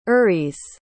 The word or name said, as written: eurys